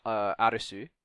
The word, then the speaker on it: Arisu: called Arisu, or which is just a Japanese way of pronouncing Alice.